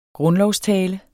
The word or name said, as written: grundlovstale